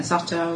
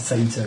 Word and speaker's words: Satyr: That it wasn't really a satire and we shouldn't pronounce it like that. – The Satyr, I believe. – Yes, but it turns out there's multiple accepted pronunciations.